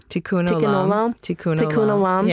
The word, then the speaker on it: Tikkun Olam: I think that this is where the Jew in me really comes out, that idea of, I don't know how to pronounce it, Tikkun Olam? – Tikkun Olam. Yeah.